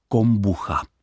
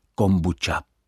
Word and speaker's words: kombuča: Pokud jde o výslovnost slova kombuča v češtině, může být dvojí. Častější je asi výslovnost, která respektuje grafickou stránku tohoto slova, totiž kombucha. Správná je ale také ta poněkud autentičtější, japonštější výslovnost, tedy kombucha.